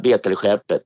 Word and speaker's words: Betelskeppet: Är det Betelskeppet eller Bettel, eller vad säger man? – Ja, Betelskeppet. Det är liksom helt okej.